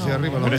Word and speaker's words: per: Es buongiorno por la mattina, que decís siempre aquí. – Buongiorno por la mattina. No, es buongiorno. Per la mattina, no por la mattina.